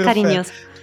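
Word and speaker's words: Carinhoso: Marisa Monte con il chitarrista Paoligno da Viola in un brano che si chiama Carignoso. Credo che sia pronun- la pronuncia corretta o sbaglio? Sì, sì, sì, sì. – Okay. Eh, okay. esatto, esatto. Carignoso ed è meraviglioso.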